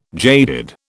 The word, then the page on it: jaded: British English